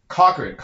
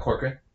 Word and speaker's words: Cochran: I would say Cochran. Cochran, right? Johnny Cochran? Right? Mm-hmm. That's how you say it, right? – Mm-hmm. – It's not Corcoran.